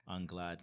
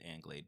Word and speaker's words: Anglade: So that's Kevin Anglade, A-N-G-L-A-D-E, and the numbers 1-1. And if my last name is throwing you off, it's French. So that's why Anglade kind of looks weird, because American way you could pronounce it Anglade, but I like Anglade as I'm, you know, climbing up the ladder.